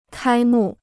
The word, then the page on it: 开幕: kāi mù